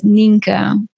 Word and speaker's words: Nienke: So my name is pronounced as Ninka. – Ninka, okay. All right, okay. – But it sounds like if you read it, a lot of people would say Nyenke.